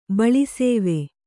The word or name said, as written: ಬಳಿಸೇವೆ